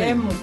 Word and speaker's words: Temu: Temu, però jo he escoltat Temu. La pronunciació que és Temu, no? Una cosa així. – Sí. Temu.